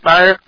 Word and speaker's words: Schneier: Is that how you pronounce his name, Bruce Schneier? – Schneier.